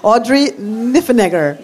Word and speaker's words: Audrey Niffenegger: Audrey Niffenegger.